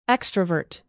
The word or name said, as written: extrovert